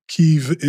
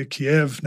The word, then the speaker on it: Kyiv: You know, as an example, Kyiv is the Ukrainian pronunciation of the capital. And if you say Kiev now, you are quickly corrected.